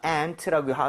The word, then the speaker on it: aunt: 아, 근데 발음할 때 이건 지역 차이입니다. 아, aunt라고 하기도 하고요. aunt. 이렇게 이 모양을 더 벌리기도 하는데 이거는 personal choice라고 볼 수가 있어요.